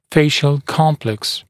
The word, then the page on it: facial complex: ‘feɪʃl ‘kɔmpleks; ‘фэйшл ‘комплэкс